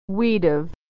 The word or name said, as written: we'd've